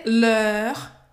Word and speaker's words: l'heure: C l'heure de, tá? L'heure. Faz a boca do O e fala é.